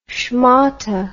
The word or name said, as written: schmotte